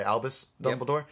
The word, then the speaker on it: Albus Dumbledore: I pronounce it right, Albus Dumbledore. – Yep. Yes, yeah, I like him in pretty much everything.